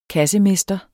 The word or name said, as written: kassemester